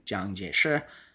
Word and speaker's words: Jiang Jieshi: In the 20th century, for instance, both the Kuomintang forces, aka the Nationalists of Republican China, under Chiang Kai-shek, or as it's pronounced in Mandarin, Jiang Jieshi,